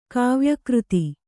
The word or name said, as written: ಕಾವ್ಯಕೃತಿ